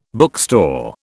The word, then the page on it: bookstore: British English